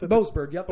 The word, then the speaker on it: Boasberg: In a 91-page decision, Judge James Boasberg, I hope I said that right. – Yep. Boasberg.